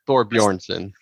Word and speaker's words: Thorbjornsen: How do you say his name? – Thorbjornsen. – Thorbjornsen. – Got it. Thorbjornsen.